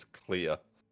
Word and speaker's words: Clear: Pronounces it as Claire a couple of times. – Yeah. – But it's spelled and written and said by Alex and maybe other characters, it's pronounced as Clear. C-L-E-A-R.